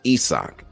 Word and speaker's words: Isaac: It looks like Isaac to us, but it's pronounced Isak.